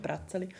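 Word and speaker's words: Brätzeli: Bitte sehr, das spricht man einfach nicht auf Hochdeutsch nein, aus. Es ist einfach Bratzeli.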